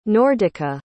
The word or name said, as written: nordica